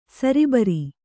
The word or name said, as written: ಸರಬರ